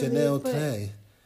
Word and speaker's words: Channel Tres: He pronounced it differently. – Chanel Tay. – But it's written as Channel Tres.